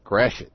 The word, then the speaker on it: Gratiot: So Gratiot is pronounced Grashit.